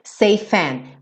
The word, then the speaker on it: safe and: J-j-j, hold you. Keep you safe and warm. Safe and warm. Seifan. Já junta, porque o E do safe não vai ser pronunciado, certo? Então você junta o F, Seifan warm. And warm.